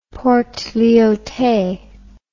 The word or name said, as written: Port Lyautey